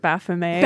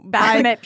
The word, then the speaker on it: Baphomet: I liked when somebody at one point pronounced it Baphomet. – Baphomet. – And I was like, it's French.